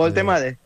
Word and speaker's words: Woltemade: El chico Woltemade.